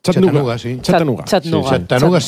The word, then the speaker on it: Chattanooga: ¿Se pronuncia así en Chattanooga? – Chattanooga. City. – Sí, sí.